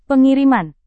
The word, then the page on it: pengiriman: indonesian